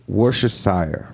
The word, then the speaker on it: Worcestershire: How'd you just say it? You just said it wrong. – Worcestershire. Worcestershire. – Worcestershire. – Sounds like a fucking word. Goddamn. – It's called Worcestershire.